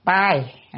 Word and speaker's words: tai: Cái chữ này đọc là tai, à, tai, cái chữ tai này lạ quá không?